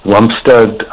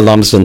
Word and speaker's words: Lumsden: Lumsden Beach.